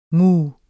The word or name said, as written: muh